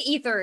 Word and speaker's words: Aether: I think Aether is the right way to say that.